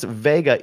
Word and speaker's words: Wega: It's pronounced Vega, even though it's spelt Wega.